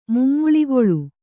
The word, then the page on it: ಮುಮ್ಮುಳಿಗೊಳ್: mummuḷigoḷ